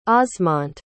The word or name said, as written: osmont